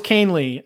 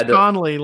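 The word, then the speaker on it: Kahnle: Didn't know it was Canely. I was this years old. I thought it was Conley, like forever.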